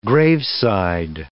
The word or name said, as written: graveside